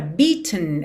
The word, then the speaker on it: beaten: Essa palavra, beaten, ela é bem interessante, porque o E não é pronunciado. Então, do T pro N, você faz um tn, tn. É quase como se fosse um som para dentro.